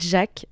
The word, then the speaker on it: DJVK: Et enfin, en première partie de tout ça, on a un autre lauréat de kick-off de cette année, qui est Jack. Jack qui s'écrit DJVK.